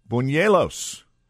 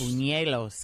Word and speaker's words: buñuelos: Their newest menu item is going to be a dessert called Buñuelos. Buñuelos. – Buñuelos, because it's got the N with the little squiggly above it. – Right. It's spelled B-U-N-E-L-O-S. So I'm going to go with Buñuelos.